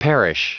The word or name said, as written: perish